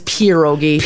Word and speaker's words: pierogi: We don't say pierogi right? – Nope. – Pierogi. – It is pierogi. – Pierogi? – Yes. It is correct. – Like pierogies? – It's pierogi. – Here's how you spell it. P-I-E.